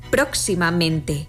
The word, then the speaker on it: próximamente: Así lo vemos en próximamente, donde se pronuncian con más fuerza las sílabas pro y men.